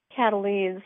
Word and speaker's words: Kataline: Cataline spelled K-A-T-A-L-I-N-E.